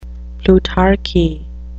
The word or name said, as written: plutarchy